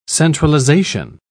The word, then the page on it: centralisation: British English